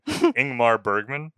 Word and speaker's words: Ingmar Bergman: I think it's a Swedish movie, Ingmar Berg. I can never say his name. Ingmar Bergman.